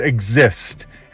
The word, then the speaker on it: Xyst: This is Exist, and it's spelled X-Y-S-T.